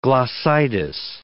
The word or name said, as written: glossitis